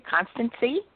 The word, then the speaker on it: Constancee: I hope I'm pronouncing it correctly. Constancy. – That's right. Yep.